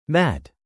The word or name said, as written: mat